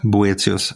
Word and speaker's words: Böthius: Eh, Gustav Böthius, eh, nu uttalar jag hans namn fel kanske. Boetius.